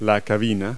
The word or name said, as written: cabin